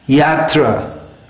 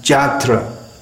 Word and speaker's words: Yatra: Yatra, pilgrimage, is pronounced Jatra, and so on.